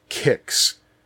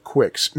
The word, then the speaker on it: Qix: By the way, everybody, Q-I-X, it is pronounced Kix. I hear people pronounce it Quix. No, it is Kix. The only reason it would ever be pronounced Quix is if there were a U after the Q, or a W after the Q. And in most languages, even with the U in there, it's still pronounced like a K, so it's Kix, Kix, Kix, Kix, Kix.